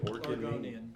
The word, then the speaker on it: Oregonian: So the actual line from The Oregonian, am I saying that right? – Oregonian. – It's a hard word.